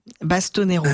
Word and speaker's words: Bastonero: C'est Sylvain Bastero. Vous étiez là? – Sylvain Bastonero. – Ah, Bastonero. Bastonero. – C'est pas grave. Oui, donc j'ai mangé une syllabe, c'est fou, quoi.